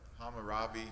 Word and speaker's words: Hammurabi: Did I say that right? – Hammurabi. – Hammurabi, okay.